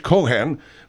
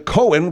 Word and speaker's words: Cohan: There's two ways of pronouncing it. There's the Irish Cohan. There's the Cohen, which is more European.